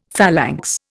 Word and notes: phalanx: Indian

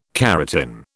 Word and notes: keratin: British English

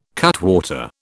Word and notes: cutwater: British English

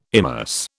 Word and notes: immerse: British English

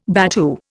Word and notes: battue: Indian

